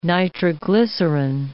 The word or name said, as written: nitroglycerin